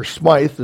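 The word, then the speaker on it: Smyth: But Smith, or Smythe, as some people might pronounce his name, Smith met Mennonites and immediately adopted credo baptism, believer baptism.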